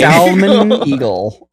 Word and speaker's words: Salmon Eagle: I love that you have to pronounce the L with it. Otherwise, it sounds too much like the original name. – Salmon Eagle.